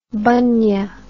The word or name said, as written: bunnia